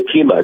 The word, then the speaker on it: Okemah: Do you pronounce it Okemah or Okemah? I'm curious. – It's Okemah. It's Woody Guthrie's birthplace.